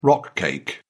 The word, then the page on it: rock cake: British English